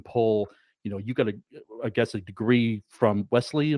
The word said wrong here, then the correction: Wellesley: am I saying it right? Wesley College? – Yeah, Wellesley College.